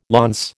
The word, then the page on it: launce: American English